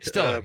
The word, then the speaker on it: Stiller: Ben Stoller was was so nice. Did I get the name right? – Stiller, yeah. Ben Stiller, sure. – Stiller, I'm sorry.